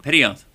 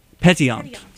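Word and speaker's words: pétillant: Petillant. – Petillant.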